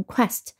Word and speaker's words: quest: 那么 Q U 这个组合呢，它发的一个辅音呢是 k 和 w，那就是 W 的这个呃辅音声音 w 和 k，k 和 w 拼在一起 q，一般都是发成 K W 的发音 k，那么和后面的 est E S T 发成 est，quest 就发成 quest。